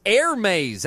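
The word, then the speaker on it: Hermès: He says it's Air Maze. That's how you say it.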